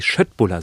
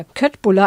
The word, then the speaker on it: Köttbullar: Die Schöttbuller, so werden sie, glaube ich, richtig ausgesprochen. – Ja, das stimmt. Das habe ich auch mal gelesen, dass alle denken immer Köttbuller.